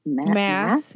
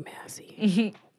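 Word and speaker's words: Maas: How do you say her last name? – Mass. Yeah. Mm-hmm. – I get hung up on pronouncing last names. I never want to mispronounce them. – No, same, because I thought it was like Massey.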